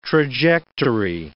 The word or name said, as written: trajectory